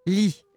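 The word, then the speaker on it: Rhee: C'est Syngman, ça s'écrit Ri, mais ça se prononce Li. Et c'est devenu Li.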